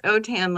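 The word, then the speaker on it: Autin: And my husband is Cajun French, and the name is pronounced Otan, like Otan my hide. It's A-U-T-I-N.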